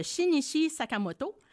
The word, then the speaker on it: Shinichi Sakamoto: Donc, c'est une œuvre de Shinichi Sakamoto. – Ah, tu es très bonne, c'est bien ça.